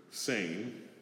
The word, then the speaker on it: sane: For example, the Hebrew word, sane, and it is actually spelled S-A-N-E, and pronounced that, but a completely different meaning than what we have in English.